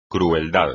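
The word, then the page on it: crueldad: español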